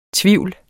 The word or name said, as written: tvivl